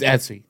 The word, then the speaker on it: Etsy: Itzy, because I made them myself. – What? – Itzy. Milk. Breast milk. We can sell them. – She means Etsy. – Etsy. I can't pronounce things, but Etsy.